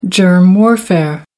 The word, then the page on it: germ warfare: American English